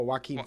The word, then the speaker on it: Joaquin: I never say his name right, but Joaquin Phoenix? – Oh, Joaquin Phoenix. – Joaquin. I knew it was.